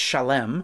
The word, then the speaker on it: Salem: Their names are Melchizedek, the king of Salem, which is pronounced Shalem, roots with Shalom, of course, but also it is the ancient spot that would become Jerusalem.